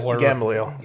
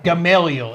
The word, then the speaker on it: Gamaliel: You're thinking of, Gamaliel. – Gamaliel. – Is that how you say it? – We Gamaliel. – Gamaliel. Yeah. – I'm, yes, you're right.